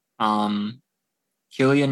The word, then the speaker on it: Cillian: Cillian Murphy, I think that's the correct way to say it, because he's very much, he says he's Irish.